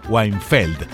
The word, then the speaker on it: Weinfeld: El personaje en cuestión era Weinfeld, así con acento en la E, Weinfeld.